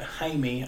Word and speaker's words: Jaime: It sets it up to be, we would in English say Jamie, but Jaime, I think is how they say it.